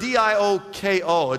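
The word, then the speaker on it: dioko (διώκω): Now, that word press on in the Greek is the, is the word D I O K O. It's pronounced dioko.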